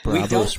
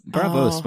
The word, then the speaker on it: Braavos: Braavos rhymes with Davos. – What? – It does. – Davos and Braavos. – Braavos, Micah.